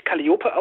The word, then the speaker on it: Calliope: Ja, also Calliope wird tatsächlich Calliope ausgesprochen. Das ist die altgriechische Aussprache.